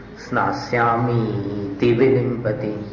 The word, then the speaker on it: स्नास्यामीति विलिम्पति: मीति ती को दीर्घ मत बोलिए, स्नास्यामीति विलिम्पति। नी को लंबा कर दोगे तो ती के लिए सांस नहीं बचेगी, तो सही उच्चारण होगा स्नास्यामीति विलिम्पति।